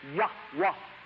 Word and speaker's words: Yahweh: Maybe something like Yahweh, but probably more like Yahweh, because the Jews like to hit that H kind of hard.